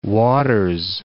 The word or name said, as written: Waters